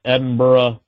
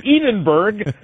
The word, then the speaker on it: Edinburgh: I learned how to pronounce Edinburgh Castle, which when I first talked about it was Edinburgh.